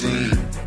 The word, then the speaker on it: zine: It's pronounced zine. Zine, zine, zine.